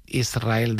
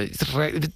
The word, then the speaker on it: Israel: Y la S con la R es difícil de pronunciar. La gente dice Israel, es verdad. – Que suene la S.